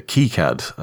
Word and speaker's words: KiCad: Well, funny point to note, in that Librem 5 article, there's a link to KiCad, as it's apparently pronounced, which...